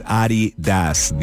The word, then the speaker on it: Adidas: But you know, that's really what it's pronounced, actually. – It is? – Yeah, it's Adidas, the founder of the brand. – Oh, and it's a German name, but put together, it's Adidas, but we call it Adidas now.